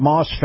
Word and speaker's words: Maus: Moss Family Automotive. Moss is spelled M-A-U-S.